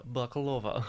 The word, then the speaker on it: Bakalova: Maria Bakalova.